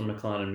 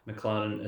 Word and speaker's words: McLaren: If you have anything nice you'd like to say about the podcast or any suggestions, please find me on Twitter at Mr. McLaren Music. McLaren is spelled like the car.